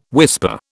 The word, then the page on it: whisper: British English